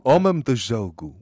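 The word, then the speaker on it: Homem do Jogo: Umam de Jogo, which is how you say it in Portuguese.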